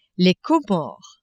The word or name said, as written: Comores